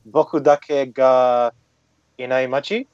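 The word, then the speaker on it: Boku Dake ga Inaimachi (僕だけがいない街): The Japanese name is Boku Dake ga Inaimachi.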